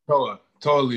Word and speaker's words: Toa Liona: Toa Liona.